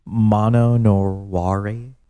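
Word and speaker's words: mono no aware: Mano no ware. – Mano no ware. – Yeah, so it's M-O-N-O and then N-O and then A-W-A-R-E. – Mano no ware.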